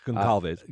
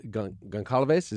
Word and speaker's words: Goncalves: Madison Mogan, Kaylee Gonzalez. – Goncalves. – Goncalves, is that how that's pronounced? – Yes.